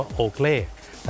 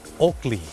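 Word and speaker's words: Oakley: โอเกลแต่เจ้าของภาษาไม่ได้ออกเสียงว่าโอเกลครับเขาออกเสียงว่าโอกลี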